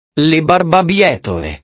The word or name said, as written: le barbabietole